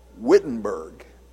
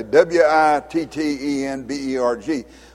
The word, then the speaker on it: Wittenberg: Now, if you saw that written down in uh, English, we would probably call it Wittenberg because it's spelled with a W, W I T T E N B E R G. But in Germany, they pronounce a W as though it were a V, and so the real name of it is Wittenberg.